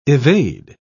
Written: evade

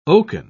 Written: oaken

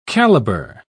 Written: caliber

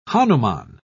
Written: hanuman

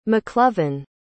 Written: mclovin

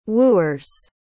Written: wooers

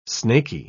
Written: snaky

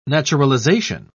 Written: naturalization

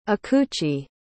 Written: akuchi

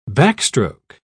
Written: backstroke

